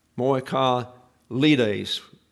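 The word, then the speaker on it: moi ka lides (μοιχαλίδες): Moi ka lides. The accent is on the next to the last syllable.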